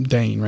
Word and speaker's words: Dane: Dane, right? D-A-N-E. And I'll say, it's like, great Dane, like, but just regular Dane.